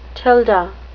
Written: tilde

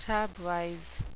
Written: crabwise

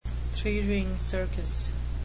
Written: three-ring circus

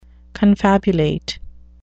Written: confabulate

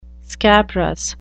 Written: scabrous

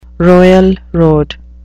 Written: royal road

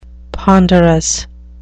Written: ponderous

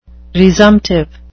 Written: resumptive

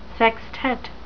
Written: sextet

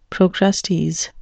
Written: procrustes

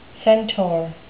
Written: centaur

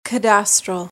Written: cadastral

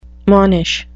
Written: monish

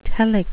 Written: telic

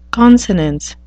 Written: consonance

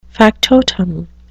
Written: factotum

